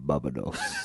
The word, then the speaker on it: Barbados: I thought that as well, but now I'm looking and I'm thinking, maybe is it Barbados? Is that right? Am I saying that right? – Barbados.